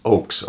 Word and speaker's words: Ochs: All right, well, the last name, because listeners can't see my name tag, is Oakes, O-C-H-S. – Yes. – And which is not pronounced Ochs. – Well, it frequently is. – Actually. I mean, people... – Yeah. Mistakenly, but yes. Oches. – Oaks.